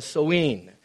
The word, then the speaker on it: Samhain: And it's spelled Samhain. Looks like Samhain, but it's Samhain.